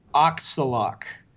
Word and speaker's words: Auksalaq: The opera is called Auxalac, which is spelled AUKSALAQ.